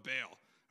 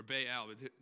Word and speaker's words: Baal: They're following an idol of Baal, or Baal, depending how you say it.